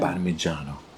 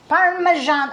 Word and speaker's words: Parmigiano: Parmigiano. – Parmesan. Oh, he's like, come on, say it right. – Because the Parmesan is the American version. – Parmigiano, do you roll it? – Parmigiano. – I'm teaching my son how to roll the R.